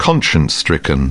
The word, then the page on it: conscience-stricken: British English